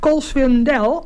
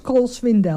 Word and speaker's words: Cole Swindell: Ja, geen Cole Swindell. – Nee, Cole Swindell. – Komt de ja, de klemtoon die hebben sommigen even anders dan dat wij denken.